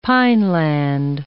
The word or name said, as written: pineland